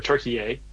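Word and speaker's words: Türkiye: or Turkey-ay, as the proper pronunciation.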